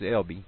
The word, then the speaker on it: Elba: It's an Ozark Heritage, Chapter 2, Elba, which is pronounced Elby, then and now.